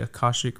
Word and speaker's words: Akashic: the Akashic records.